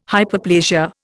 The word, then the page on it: Hyperplasia: Indian